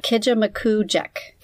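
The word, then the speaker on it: Kejimkujik: Kijimukujik.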